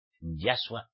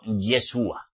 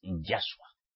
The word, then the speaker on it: Yahshua: Y hay que pronunciar su verdadero nombre. Yahshua el Mashiach. Tú no puedes pronunciarlo Yeshua. Como muchos llamados mesiánicos lo pronunciáis. Porque Yeshua no es su verdadero nombre. Es Yahshua.